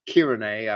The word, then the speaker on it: Cyrene: And he was probably an Israelite dwelling in Cyrene, which is Kyrene, I would say, pronounce it.